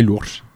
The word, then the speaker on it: Loures: C'est Lourdes, voilà. – C'est Lourdes, mais c'est... – Ça s'écrit Lourdes, L-O-U-R-E-S,